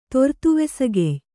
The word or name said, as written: ತೊರ್ತುವೆಸಗೆಯ್